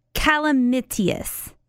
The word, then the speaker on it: calamitous: Did you say chlamydia? – Chlamydious? Calamitous. See if you know what this word means. – Calamitous? Calamitous? – Spell it for me. – Calamitous. Like calamity? Calamity? – Yeah.